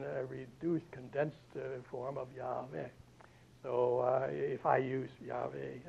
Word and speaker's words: Yahweh: And so this, again, is another complicated matter, but the best educated guess is that the pronunciation of it was Yahweh, Y-A-H-W-E-H, with however strange the sound, huh? Yahweh, nevertheless, that apparently, it's been argued, disputed, but the majority of scholars would agree that that was the proper pronunciation, Yahweh.